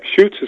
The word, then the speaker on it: Schuchts: Yep, Schuchts is correct.